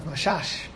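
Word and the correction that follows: Messas: I'm pronouncing it wrongly? – Masas. – Okay, Rav Shalom Masas.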